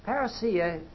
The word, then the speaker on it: parousia: Parousia is a Greek word, as you know. And incidentally, I understand from checking on the pronunciation that my anglicized Greek version is pretty close to being right. No, parousia.